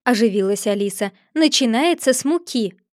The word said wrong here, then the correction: муки: «Ты делаешь неправильное ударение», — перебила ее Черная Королева. – «Надо говорить не муки, а муки.